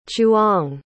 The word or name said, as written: chuong